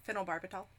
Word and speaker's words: phenobarbital: Oh, how do you say it again? – Phenobarbital. – Thank you.